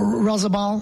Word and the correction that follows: Oyarzabal: how you say his name? – Oyarzabal. – Oyarzabal, that's right.